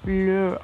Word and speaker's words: leur: Ele tem um S no final da escrita, só que não pronuncia, então acaba ficando igual ao singular. Leur.